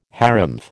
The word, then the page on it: harrumph: American English